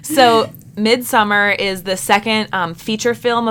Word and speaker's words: Midsommar: Midsommar, although I've heard that Ari Aster says it's supposed to be pronounced Midsommar, which, like, that's hard for me.